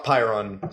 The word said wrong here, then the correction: prion: disease, just like mad cow. – Prion? – Prion. However you pronounce it.